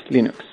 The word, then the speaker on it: Linux: Hello, this is Linus Torvalds and I pronounce Linux as Linux.